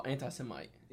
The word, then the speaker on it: anti-Semite: Oh, anti-Semite. – Yeah, anti-Semite. I mispronounced it.